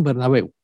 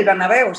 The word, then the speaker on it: Bernabéu: Así como se pronuncia, ¿no? Bernabéu. – Sí, el Bernabéu, sí,